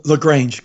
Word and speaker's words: LaGrange: LaGrange, correct.